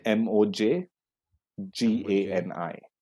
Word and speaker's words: Mojgani: M O J G A N I.